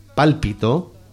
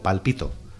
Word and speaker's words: pálpito: Y además que tengo que darosla con la sílaba tónica cambiada para ayudaros, porque aquí dice pálpito, pero yo os la vendo como palpito.